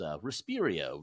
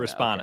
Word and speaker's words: Respona: And I could not pronounce the company right. I was Respirio, Responia, and all these different things, but it was Respona, right? Am I saying it right? Or am I probably saying Respona.